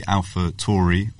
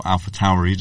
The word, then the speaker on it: AlphaTauri: Yeah, the only other thing to mention from today is the launch of the AlphaTauri, or AlphaTauri, depending on how you say it.